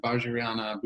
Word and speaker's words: Vajrayana: And then Sarah is trained in a Tibetan language lineage of Buddhism and practices both Mahayana and Vajrayana Buddhism. Did I even get that close? It's fairly... – Yep, that was good pronunciation. Perfect.